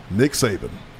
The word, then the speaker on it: Saban: Second year, yeah, I had four with Nick Saban. Rhymes with, you know, Satan, some people would say back then, because he was so intense, man.